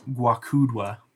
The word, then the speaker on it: Gwakudwa: Guakudwa. It's spelled Gwakudwa.